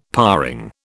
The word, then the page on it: paring: British English